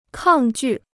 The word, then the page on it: 抗拒: kàng jù